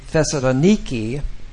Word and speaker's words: Thessaloniki: In Greece, they pronounce the city Thessaloniki.